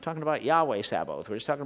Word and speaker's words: Sabaoth: By the way, Sabaoth must not be confused with a somewhat similar word, Sabbath. They're totally different, so it's not Sabbath. We're talking about Sabaoth. Short A, oath, long O. Sabaoth is how you pronounce that in the Hebrew, Sabaoth.